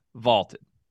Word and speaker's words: Vlted: That's Vaulted, V-L-T-E-D, pronounced Vaulted.